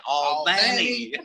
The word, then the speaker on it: Albany: Now, you got to say it right. Albany.